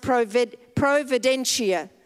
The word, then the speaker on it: providentia: It's a Latin word, providence, and it means, you know, in Latin it's pronounced providentia.